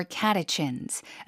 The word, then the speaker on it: catechins: Matcha contains more catechins, a type of antioxidant than regular green tea. – Catechins라고 읽습니다. 영어에서는요. 발음 다시 한 번요. – Catechins. – 앞에 힘 주고 catechins.